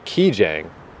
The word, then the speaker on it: Gijang: G-I-J-A-N-G, but I looked into it a bit, how you pronounce it, and it's like Kijang with a K sound.